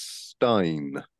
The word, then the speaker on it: Stijn: Because well, it's spelled Stijn. And I was like, what is that? Stijn? Stijn? I don't know. – Just those, Stijn. – Yeah, Steve. And it turns out, right, he's Dutch.